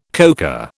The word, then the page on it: coca: British English